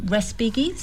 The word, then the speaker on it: Respighi: And I don't know how to say this name, Respighi. Yes. – Pines of the Appian Way, or Appian Way. – Right. I actually just had a quick look at the Holst and also Respighi.